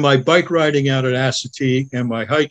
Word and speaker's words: Assateague: We've dug out these places, and So the Assateague, is that how you say it? How do you say it? Assateague? – Assateague.